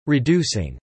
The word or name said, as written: reducing